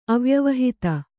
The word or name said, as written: ಅವ್ಯವಹಿತ